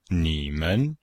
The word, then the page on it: ni3men5: pinyin